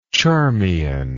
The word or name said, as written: charmian